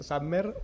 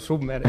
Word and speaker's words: Submer: ¿Puede pronunciarse con inglés, Safmer, o español, Submer?